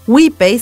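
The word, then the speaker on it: Wipei: WePay se pronuncia y se lee tal como se escribe, W-I-P-E-I, e pero ese truco de pronunciación la termina asemejando a las palabras en inglés we pay, que significa nosotros pagamos.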